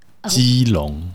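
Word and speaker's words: Keelung: How to say it in Chinese? – Keelong. – Oh. Keelong.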